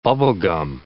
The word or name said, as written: bubblegum